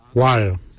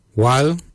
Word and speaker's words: waly: Jup is spelled D-J-O-B, jup. Jup is your kidney. Lungs, we say wal. Wal is your lungs. Wal is spelled W-A-L-Y, wal.